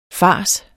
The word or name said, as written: fars